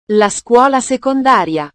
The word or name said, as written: la scuola secondaria